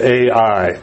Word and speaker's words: Ai: It's spelled A-I. I've always pronounced it Ai, and I believe that's correct.